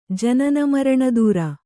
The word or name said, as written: ಜನನಮರಣದೂರ